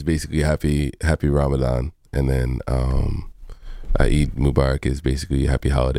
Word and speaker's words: Eid Mubarak: I saw that, because it was my first time ever hearing about this, but Mubaraka, am I saying it right? Like, it's a holiday, it was in the... – Eid. Okay, I was at Eid Mubarak.